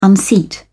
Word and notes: unseat: British English

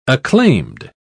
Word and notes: acclaimed: British English

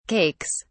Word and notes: cakes: British English

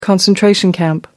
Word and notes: concentration camp: American English

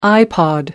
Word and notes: ipod: American English

